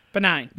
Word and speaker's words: benign: It was I can't ever pronounce it like binding. – Benign. – Benign, that's it. Benign, not binding.